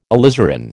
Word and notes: alizarin: American English

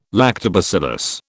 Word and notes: lactobacillus: British English

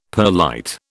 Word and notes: perlite: British English

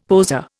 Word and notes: poser: Indian

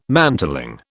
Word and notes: mantling: British English

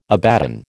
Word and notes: Abaddon: American English

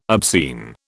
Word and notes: obscene: British English

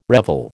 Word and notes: revel: American English

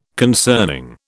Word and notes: concerning: British English

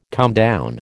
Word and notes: comedown: American English

